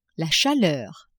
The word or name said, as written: chaleur